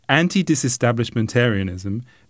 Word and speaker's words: antidisestablishmentarianism: And I'm guessing you pronounce that anti-disestablishmentarianism. Anti-disestablishmentarianism. – Right, yeah, yeah, perfect. Good job.